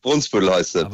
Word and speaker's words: Brunsbüttel: Ich habe immer Brunsbüttel gesagt. Immer. – Ja, richtig. Brunsbüttel heißt es. Wird in den Medien auch falsch gesagt. – Brunsbüttel. Ne? Also wir merken genau. Uns das für alle Zeiten. Es heißt Brunsbüttel und nicht Brunsbüttel. So, richtig.